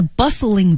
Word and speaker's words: bustling: 又是一个从前的故事了。Once upon a time, 经典的传说开始。In a bustling village, 这个单词好 ,B-U-S-T-L-I-N-G. – Yeah, bustling, so you don't really pronounce the T in the word, you say bustling.